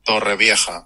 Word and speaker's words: Torrevieja: Torrevieja.